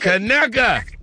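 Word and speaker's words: Conecuh: Kanaka! That is how you say it.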